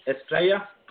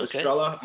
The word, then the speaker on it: Estrella: No, I mean, it's Estrella, but it's also Estrella. I mean, if you're going to anglicize it, I mean, it's fine, though.